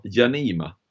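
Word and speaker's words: Jaanimaa: Men på fjärde plats, eh, Dener Janima. Mm. Kan det vara rätt uttalat? – Janima. – Janima, tack.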